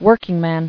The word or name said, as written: Workingmen